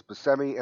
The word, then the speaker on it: Buscemi: I mean, that's the other thing is Buscemi, and to be fair, Buscemi pronounces his name both ways. He does Buscemi and Buscemi.